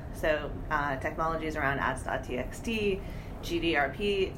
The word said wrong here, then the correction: GDPR: did I say that right? GDPR? Wow, second time.